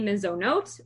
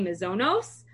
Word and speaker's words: mezonot: then we're going to say borei minei mezonot, or if you pronounce it like an Ashkenazi, borei minei mezonos, everybody refers to these things as mizonos.